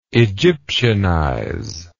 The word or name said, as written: Egyptianize